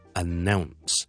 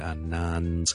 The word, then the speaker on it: announce: 今日同大家讲一个字嘅发音，因为呢小叔发觉呢好多人都读得麻麻地，但係呢个呢系我哋成日都见到嘅字嚟嘅，就系、是、呢我哋宣布一啲事 ,to announce something, announce。广东人呢我哋通常都会读成 announce 或者 announce 咁样，其实系应该读 announce, announce, announce。